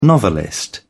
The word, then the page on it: novelist: British English